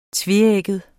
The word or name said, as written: tveægget